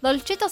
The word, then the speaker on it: Dolcetto: Dolcetto se escribe como se pronuncia y con doble T al final, ¿sí? – Como si fuera dulce. – Claro, todo. En italiano.